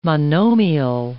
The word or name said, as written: monomial